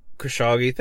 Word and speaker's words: Khashoggi: Khashoggi, thank you.